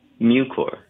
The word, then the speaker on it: Mucor: Mucor. Is that how you pronounce it? – Mucor. – Mucor. Okay.